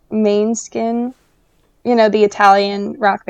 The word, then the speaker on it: Måneskin: It's Danish, means moonlight. Måneskin.